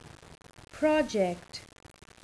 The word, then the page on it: project: noun